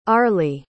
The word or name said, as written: arleigh